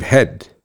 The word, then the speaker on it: Hedt: So we're going to have Mr. Dave Head. His name is pronounced H-E-A-D, like your head, but it's spelled H-E-D-T.